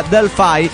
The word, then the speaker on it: Delphi: Se pronuncia Delphi, porque ay, hace 5 años que le venimos aumentando la madre a Delphi, sí.